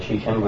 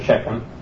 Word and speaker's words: Shechem: That's right, Shechem or Shechem. Or Shechem. There's several ways you can pronounce the word, and they're all correct, depending on the vowels, as long as you're consistent all the way through.